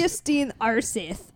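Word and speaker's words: Øystein Aarseth: Øystein Arseth. Yes. His name is Øystein Arseth.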